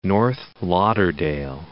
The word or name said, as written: North Lauderdale